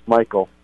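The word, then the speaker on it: Mikel: Mikael, or is it Michael or Mikael? – Michael. – Michael Good, spelled with a M-I-K-E-L.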